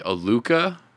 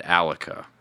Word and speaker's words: Aluka: It looks like Aluka, but we pronounce it Alaka. So it... The best way we always tell people is if you think Metallica, take the Met out of it and then just leave Alaka. And that's basically how you say it.